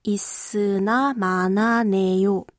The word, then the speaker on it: 있으나 마나네: Also isna mana neyo.